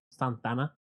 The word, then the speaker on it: Santa Ana: Of Santa Ana, I got a jury summon. – Please pronounce it correctly. It's Santana.